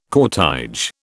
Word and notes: cortège: British English

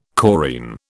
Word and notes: chorine: British English

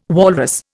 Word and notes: walrus: Indian

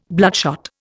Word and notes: bloodshot: Indian

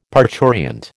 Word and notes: parturient: American English

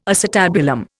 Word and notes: acetabulum: Indian

